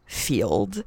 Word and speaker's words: Feeld: The second half of this episode will be discussing my entry back into the world of Field.